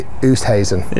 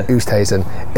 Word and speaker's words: Oosthuizen: Louis Oosthuizen. – Yeah. – I seem to always call it Louis Oosthuizen. Louis, yeah. Oosthuizen.